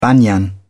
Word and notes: banyan: British English

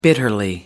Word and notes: bitterly: American English

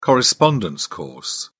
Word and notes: correspondence course: British English

